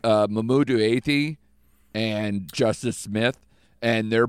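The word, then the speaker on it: Mamoudou Athie: Mamoudou Athey.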